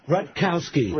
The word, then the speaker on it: Rutkowski: No, Chris Radowski? – Rutkowski. – Rutkowski.